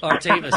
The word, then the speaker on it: Artemis: You've got to pronounce it correctly. It's called Artemis. – Artemis.